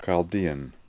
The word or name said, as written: Chaldean